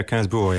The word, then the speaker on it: Gainsbourg: So the song from your youth is this one by Serge Gainsbourg. – Oh, that's how you pronounce it, isn't it? – Yeah, Gainsbourg, yeah. – I would have said Gainsbourg, but it's Gainsbourg.